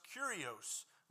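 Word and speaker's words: kurios (κύριος): That word comes from a Greek word that is pronounced kurios, related to the word kuros.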